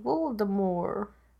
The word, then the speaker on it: Voldemort: So they call him Voldemort, like, how he was supposed to be pronounced. – Yeah, it's French. – Yeah, but it's just fun because like, that's how it's supposed to sound.